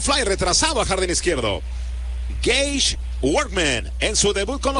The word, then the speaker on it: Gage: Gage, se escribe Gage, G-A-G-E.